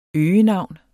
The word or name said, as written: øgenavn